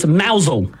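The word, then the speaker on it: Mousehole: Oh, it's actually mousel. – Mousel. – But it's spelt mouse hole. – It is spelt like mouse hole.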